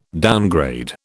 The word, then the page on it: downgrade: British English